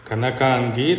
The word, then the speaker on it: Kanakangi: Kanakangi,